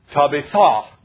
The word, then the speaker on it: Tabitha: And it's Tabitha. That's how it's pronounced in the Greek.